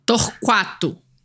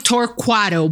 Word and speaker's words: Torquato: So is it Torcado or how do you say her last name? – Torquato. – Torquato. – Yeah, if you say it, you could say it Americanized, I'll allow it. Mm-hmm. yeah, it would sound like Torquato, but you do pronounce the U. – Torquato. – Yeah, it makes a qua sound.